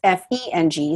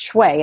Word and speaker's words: Feng Shui: So it sounds like F U N G, but it's F E N G. So that's Feng. And the last word is uh, Shui. And it looks like Shui, but it's pronounced Shui. And it's S is in Sam, H U I, Feng Shui.